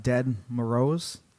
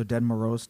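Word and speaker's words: Ded Moroz: Dead Morose. – Like dead? – Yeah, it's spelled D-E-D. – Okay. – And then M-O-R-O-Z. So Dead Morose, Dead Morose.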